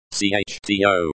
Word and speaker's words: Что: Что. This means, that, and is pronounced, CHTO.